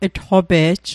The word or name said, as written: tqope'j